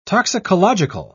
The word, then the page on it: toxicological: British English